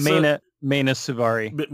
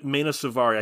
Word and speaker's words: Mena Suvari: Basically, the chick from American Beauty, Milana Suavi or something like that, she hits Mena Suvari. – Mena Suvari, I can never pronounce her name.